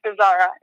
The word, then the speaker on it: Bezara: It's Bezara.